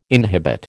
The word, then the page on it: inhibit: American English